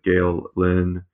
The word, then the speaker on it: Gael Linn: Gael Linn. I'm sure I'm mispronouncing that. I apologize. Uh, no, no, you're not. You're not. That's perfectly pronounced.